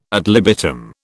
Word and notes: ad libitum: British English

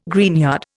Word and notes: greenyard: Indian

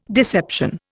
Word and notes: deception: Indian

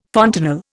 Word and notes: fontanel: Indian

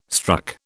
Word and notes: struck: British English

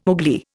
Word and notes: Ugli: Indian

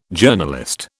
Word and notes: Journalist: British English

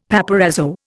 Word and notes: paparazzo: Indian

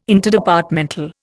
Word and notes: Interdepartmental: Indian

